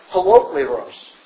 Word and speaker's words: holokleros: The Greek word for complete is pronounced holokleros, which has the idea of being whole. The prefix holo is where we get the English word for holograph.